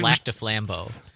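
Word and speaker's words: Lac du Flambeau: How do you say it? – Lac de Flambeau.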